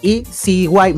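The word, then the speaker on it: EZY: el staff de anime Patlabor EAC e a ver, ECY, mejor dicho, disculpen eh, por el error de pronunciación,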